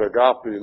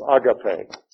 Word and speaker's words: agape: Uh, it is true that if you are pronouncing it in the Greek form, it's agape. But most people in the English form say agape. Alright? It is easier. But in most European languages, if there are three syllables in a major word, the accent is always on the first syllable, all right, which would be agape.